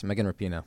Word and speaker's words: Megan Rapinoe: Megan Rapinoe. – Yes, Megan Rapinoe.